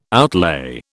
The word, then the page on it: outlay: British English